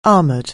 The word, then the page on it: armor: anglais